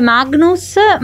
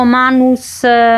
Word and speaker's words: Magnus: In realtà non si pronuncia neanche Magnus, ma mi ha detto una roba tipo Manus. Manus Millang, che è uno dei protagonisti, perché è un film corale, infatti, un po' più concentrato su Mads Mikkelsen, ma in generale corale.